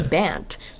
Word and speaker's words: Bant: Yeah, I'd have to say Bant. But I hate saying Bant, because that's not how you say my name.